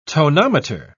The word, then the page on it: tonometer: British English